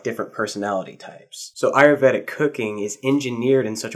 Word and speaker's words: Ayurvedic: Are you Ayurvedic. – Ayurvedic.